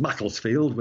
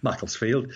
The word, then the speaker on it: Macclesfield: It's in the north of England, so it's pronounced Macclesfield with a very short flat A, I, Macclesfield.